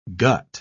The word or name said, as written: gut